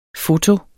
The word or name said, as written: FOTOENES